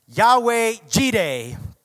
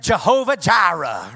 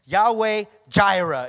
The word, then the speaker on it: Yahweh Jireh: Come on, say, Yahweh Jireh. More commonly said, Jehovah Jireh, right? And actually, in Hebrew, it would be spelled with a Y, the, the Jireh part, okay? Yahweh Jireh, if you will.